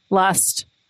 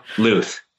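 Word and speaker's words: Lusth: Luth.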